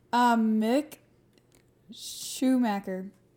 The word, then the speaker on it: Mick Schumacher: Mick Schumacher.